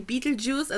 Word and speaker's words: Betelgeuse: Eigentlich so Betelgeuse aber natürlich, Beetlejuice kannst du halt besser, also es wird so ausgesprochen wie Beetlejuice, also wie Käfersaft und deswegen erklärt er es auch mit diesen Worten.